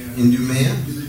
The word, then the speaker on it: Idumea: And from Jerusalem and from Idumea, anybody know how to pronounce that better than that? Because that sounds pretty dumb. – Idumea. – I appreciate that.